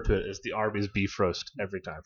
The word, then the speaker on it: Bifrost: And it is pronounced Bifrost. – Bifrost? – Bifrost.